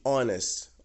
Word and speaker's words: honest: Diferente, por exemplo, de honest, honest man, an honest man, porque tem som de O, o H não é pronunciado, beleza?